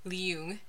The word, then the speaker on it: Leung: Leung is how you say his last name.